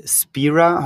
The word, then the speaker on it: Spira: wie sich SpareTech dann weiterentwickelt hat mit Spira. Habe ich das richtig ausgesprochen, Bernd? – Ja, korrekt.